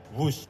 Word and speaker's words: Whoosh: Kalgi WHOOSH, tapi membacanya WUSH.